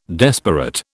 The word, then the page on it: desperate: British English